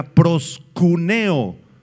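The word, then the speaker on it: proscuneo (προσκυνέω): La palabra adoración es la palabra griega proscuneo, con K y acento en la E. Proscuneo.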